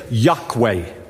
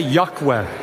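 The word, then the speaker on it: Yokwe: Just tell the person by the side of you, say, Yahweh. Say it one more time. Say, Yahweh. It is spelled Y-O-K-W-E. Y-O-K-W-E. That's how they spell it.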